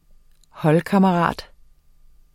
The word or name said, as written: HOLDKAMMERATEN